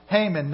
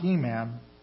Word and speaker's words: Heman: So the Levites appointed Heman, and that's how it's pronounced, Heman, not Heman,